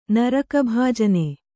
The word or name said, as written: ನರಕಭಾಜನೆ